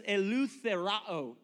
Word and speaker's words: eleutherao: It's pronounced eleutherao. Eleutherao is what it says.